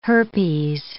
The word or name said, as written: herpes